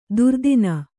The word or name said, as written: ದೊರೆತನ